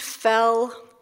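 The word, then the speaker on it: Foell: My name is Amy Fell, F as in fish, O-E-L-L, pronounced Fell. Yes, the past tense of fall.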